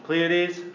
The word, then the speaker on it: Pleiades: After that, they become, I don't know how to pronounce this, the Pleiades, this constellation of stars. Pleiades, how do you say it? – Pleiades. – Okay, the Pleiades, which is a constellation.